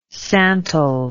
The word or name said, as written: santal